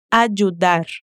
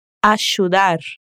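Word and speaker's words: ayudar: O segundo detalhe que também indica de onde Agostina é, é o sotaque dela ao dizer ajudar. A pronúncia dela é assim. Tenta me imitar. Ajudar. Ajudar. Isso acontece bastante no sotaque portenho, ou seja, de Buenos Aires, por exemplo. O som do Y e dos dois L's é como se fosse um SH, como na palavra shampoo, por exemplo.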